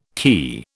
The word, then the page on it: kea: British English